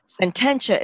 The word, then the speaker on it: Sententia: So Sententia is spelled S as in Sam, E-N as in Nancy, T-E-N like 10. And then TIA.